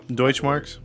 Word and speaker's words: Deutschmark: The civil case concluded, and the court ordered Mercer to pay Michael's 7,000 Deutschmark money. What's the word? – Deutschmarks? – I don't pronounce it.